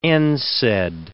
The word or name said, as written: NSAID